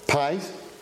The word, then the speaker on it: pais: I'll attempt to pronounce it correctly, and Shahi can correct me if I'm wrong. Pais, P-A-I-S, which means child or boy, but could also mean servant.